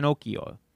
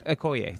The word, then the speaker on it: Okoye: I was surprised they didn't re-release an Okiyo. – Okoye? – Okoye.